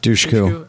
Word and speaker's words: Dushku: I'm like, it's hard to look at Eliza Dushku, or how do you say her last name? I always Dushku.